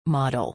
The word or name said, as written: model